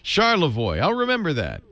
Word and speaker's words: Charlevoix: Charlevoix. – Charlevoix. All right. Charlevoix.